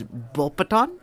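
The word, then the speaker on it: Beauxbatons: The next one is Bulpeton. The S is silent, by the way.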